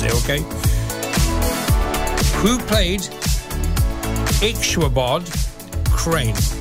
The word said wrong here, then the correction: Ichabod: Who played Ixchabod Crane? That is spelled I-C-H-A-B-O-D. In Sleepy Hollow. Who played Ichabod Crane in Sleepy Hollow? Spell I-C-H-A-B-O-D. Ichabod. And be very careful with this one as well.